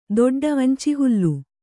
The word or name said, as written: ದೊಡ್ಡ